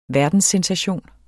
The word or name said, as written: verdenssensation